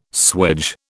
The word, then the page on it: swage: British English